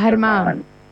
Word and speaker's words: Germán: So the G sound is pronounced like a H, and then you put an accent at the end. So it's pronounced Germán.